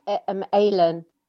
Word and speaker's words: Aylen: Is it Aylin or Island? – It's Aylin.